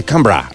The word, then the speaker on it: Canberra: Que no se dice Canberra, se dice Canberra. – Canberra.